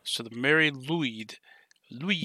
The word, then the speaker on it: Lwyd: It's the Mary Lwyd is how you'd probably say it. – Lwyd? – Lwyd. Yeah. W in Welsh is basically can be a consonant, but it's also a vowel, and it can have an oo sound when it's a vowel.